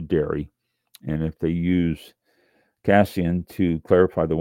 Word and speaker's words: casein: Is am I pronouncing that right? It's got a little thing. Casin. Oh, well, I wasn't pronouncing it right. Cassian, C-A-S-E-I-N, Cassian.